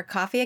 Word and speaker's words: Ko-fi: We had a really generous donor who gave to our coffee account. And by the way, we say coffee, but it really is spelled Ko-fi.